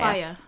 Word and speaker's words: Playa del Rey: He is a software salesman from Playa del Rey. Is that how you pronounce that? Playa? I think it's Playa. – Playa. Like Playa. Beach?